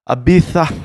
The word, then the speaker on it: Ibiza: Ibiza, that's how you say it.